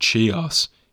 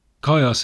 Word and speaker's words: Chios: I was pronouncing it Chios in previous episodes. I do apologise. My pronunciation of Greek places is pretty shabby sometimes. Chios is that little island city-state off the west coast of Anatolia.